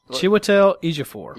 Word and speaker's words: Chiwetel Ejiofor: It's Chiwetel Ejiofor.